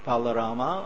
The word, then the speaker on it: Balarama: you can either call him Rama, Balarama, or Balaram.